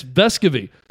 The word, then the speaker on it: Vescovi: Tennessee Media Relations said, we've been saying it the wrong way, and we were never corrected by him, and it's Vescovi.